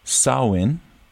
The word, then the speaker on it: Samhain: Halloween's roots can be traced back to ancient Celtic festivals, particularly Samhain, which is spelled S-A-M-H-A-I-N.